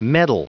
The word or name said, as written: meddle